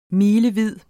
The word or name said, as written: milevid